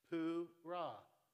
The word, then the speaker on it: Purah: That's how you pronounce it. Purah.